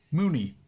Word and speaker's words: Mooney: Like, a known thing of Spider-Man has been like, Mooney rhymes with loony.